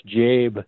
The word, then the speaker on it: Jabe: It's not Jab. It's Jabe. – Jabe. Oh, sorry, Jabe.